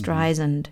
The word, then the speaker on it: Streisand: Siri hat den Namen falsch ausgesprochen, denn sie spricht sich aus wie der Sand auf Englisch hinten. Also Stry Sand, nicht mhm. Streisand.